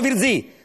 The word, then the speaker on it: Virzì: Lui, Paolo Virzì, con l'accento sulla I.